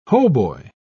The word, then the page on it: hautbois: British English